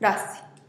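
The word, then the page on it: rasih: Teotitlán del Valle Zapotec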